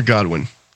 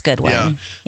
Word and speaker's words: Godwin: Is it Goodwin or Godwin? – Well, it's Godwin. Spelled Godwin, but it could be pronounced Goodwin.